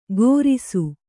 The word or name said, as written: ಘೋರಿಸು